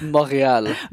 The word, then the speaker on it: Montreal: So, Montreal, I think that's how I'm supposed to pronounce it. – It's Montreal.